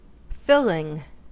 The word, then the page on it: filling: American English